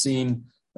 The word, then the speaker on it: Sin: It's really probably better pronounced Sin. This is not sin as in, wow, there's a lot of sin there. It's just the name of the place, S-I-N-Sin. It just ends up being spelled the same way that we spell the word sin, but I don't want people to get confused about that.